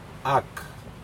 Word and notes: ak: Aneityum

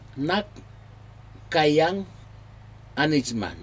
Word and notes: nakaiyag anijman: slow

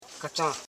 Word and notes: kachan: ka.chan; Koro

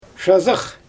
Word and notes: ʃazɯx: Xyzyl

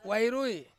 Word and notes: wai rui: Ansus

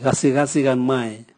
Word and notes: gasigasi gan mwae: Raga

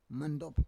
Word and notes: mandob: Roon

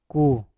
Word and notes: ku: Mpur

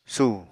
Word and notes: sù: apatani

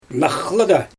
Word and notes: naxlyGa: Xyzyl

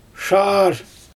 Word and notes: ʃaar: Xyzyl

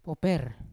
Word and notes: woper: Yawa

